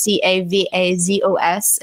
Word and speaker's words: Cavazos: So Christina spelled with the CH and then Cavazos, which is Cavazos.